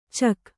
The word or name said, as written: ಚಕ್